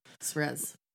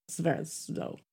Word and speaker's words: Svrzo: Jane Serves, I do not know how to say her name. S-V-R-Z-O. – Svrez. – Svrezo.